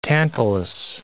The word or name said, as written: Tantalus